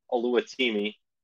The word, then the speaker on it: Oluwatimi: Oluwatimi, transfer from Virginia, offensive lineman, plays center. I can't believe I said his name right, because I never do.